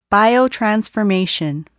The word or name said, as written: biotransformation